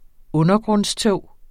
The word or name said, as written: undergrundstog